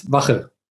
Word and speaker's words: Wache: Uh, yeah, it's German. It's Wache.